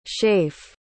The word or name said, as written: scaife